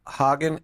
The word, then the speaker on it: Haugen: I think her name is pronounced Haugen, H-A-U-G-E-N.